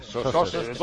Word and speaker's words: Soses: Y nos dice Víctor que lo del accidente de los ciclistas ha sido en Soses, o Soses, ¿cómo se dice? – Soses, Lleida. En Lleida, sí. – Lleida, no, no, como se diga, no, como se dice. – Soses, toda la vida, no lo han cambiado.